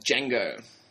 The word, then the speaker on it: Django: And there is a slave called Django. – Now, that's spelt with a D. – Yes. It's silent.